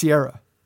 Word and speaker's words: Ciara: Is it Ciara or Ciara? – It's Ciara.